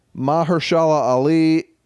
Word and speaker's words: Mahershala Ali: Mahershala Ali, which I realize as I read it, I've been mispronouncing for 2 years now. Mahershala Ali in Green Book.